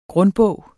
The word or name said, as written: grundbog